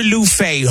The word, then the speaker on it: Luffeigh: My name is Luther Lufe, homie. – What kind of loser name is that anyway? – L-U-F-F-E-I-G-H. The first F is silent.